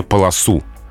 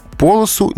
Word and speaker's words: полосу: И вот здесь есть два варианта на полосу и на полосу. При этом они все-таки неравнозначные. А самые строгие словари, адресованные работникам эфира, рекомендуют ударение полосу оно предпочтительнее. Но полосу не ошибка. То есть здесь навигатор не противоречит нормам русского языка. Нам с вами в эфире перед микрофонами лучше сказать полосу.